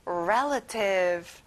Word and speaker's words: relative: 친척, relative. 자, 가운데 A 발음은 잘 안 나요. 다시 한번, relative.